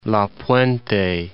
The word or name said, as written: La Puente